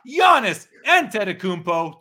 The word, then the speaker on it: Antetokounmpo: Giannis Antetokounmpo, 2013 Panini Finals rookie Mem Jersey Patch Auto rookie card.